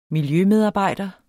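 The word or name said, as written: miljømedarbejder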